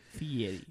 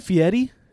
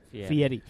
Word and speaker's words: Fieri: Guy Fieri. – Isn't it Fieri? – Fieri. – Yes. – But you got me all riled up. – Said it wrong. – You said it wrong, dude.